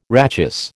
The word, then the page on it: rachis: American English